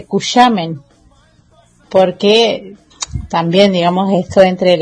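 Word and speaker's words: Cushamen: Por lo general, o generalmente, los cusameneros, cusameneras, decimos Cusamen, así como se escribe, nombramos de esa forma o leemos de esa forma.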